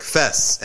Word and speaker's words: Fez: City of Fez. Pronounced in Arabic, Fez.